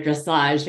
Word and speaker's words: dressage: And thanks for the history of dressage, because I was like, wow, that's, you know, first of all, thanks for the pronunciation. It's like French, right? Dressage.